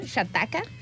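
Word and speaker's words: Xataka: Hataka, ajá, Shataka. – Está, bueno, se escribe con X.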